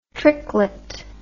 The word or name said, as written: tricklet